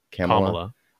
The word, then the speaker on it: Kamala: But yeah, I guess that Jim Carrey is going to be the recurring along with Maya Rudolph for Kamala. Yes, got it. – Or Kamala. – Yeah. – I feel like they said that even Biden's been mispronouncing that.